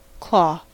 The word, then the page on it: claw: American English